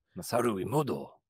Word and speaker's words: Masaru Emoto: So regarding the famous experiments, we'll throw it on the screen here with Emoto, it's Masaru Emoto, is that how you say it? – Masaru Emoto.